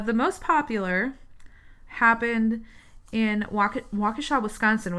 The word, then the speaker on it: Waukesha: I say Waukesha. and that's the right way to say it.